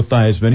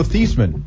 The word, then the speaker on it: Theismann: He, of course, was known as Joe Theismann when he came right. to Notre Dame in the late 1960s. So he famously later changed the pronunciation of his name to rhyme with Heisman in 1971 at the suggestion of another Notre Dame great, Roger Valdeseri.